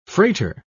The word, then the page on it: frater: British English